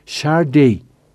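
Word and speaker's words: Sade: la pronunciación Shade por si había confusiones con un cierto marqués de, origen francés.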